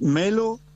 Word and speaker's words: Melo: Es Melo y Kinsikeu. Se escribe de una forma y sí. se pronuncia de otra. – Bueno, Lucas, nosotros somos Melo con una sola L.